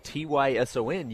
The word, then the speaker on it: Taison: T A I S O N, pronounced Tyson.